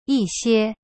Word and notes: 一些: yi xie

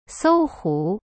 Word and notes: 搜狐: sou hu

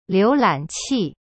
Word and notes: 浏览器: liu lan qi

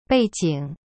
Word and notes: 背景: bei jing

